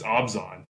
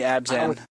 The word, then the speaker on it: Abzan: And wasn't there a different way to pronounce Abzan? – Abzan? Obzon? – I think I got corrected. Someone thinks it's Obzon. So. – It's definitely Abzan.